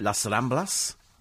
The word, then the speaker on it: Las Ramblas: The Las Ramblas, I think that's how you pronounce it, is it? Las Ramblas? Yeah. Yes, yes, yes, okay, thank you.